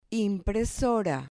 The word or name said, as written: impresora